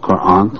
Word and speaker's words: Quran: I have to brush up on my pronunciation myself of Quran, long A.